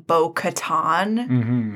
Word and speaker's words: Bo-Katan: I will say, I actually love that Fenn Rau pronounces her name Bo-Katan, because it sounds like katana, which is way cooler.